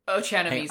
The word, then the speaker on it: Ochanomizu: I can't pronounce Dr. Ochanomizu. – I've written it down so many different ways, and that's going to be a fun bit. Ochanomizu.